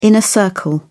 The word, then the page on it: inner circle: British English